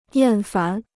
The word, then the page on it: 厌烦: yàn fán